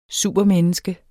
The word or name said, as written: supermenneske